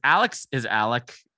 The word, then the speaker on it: Alex: It, but Alex is Alec and Murdaugh is Murdoch. So that's just how South Carolina rolls.